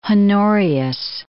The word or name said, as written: Honorius